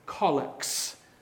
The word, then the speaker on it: collect: We have narthexes and collects. That is how you pronounce that word, collect.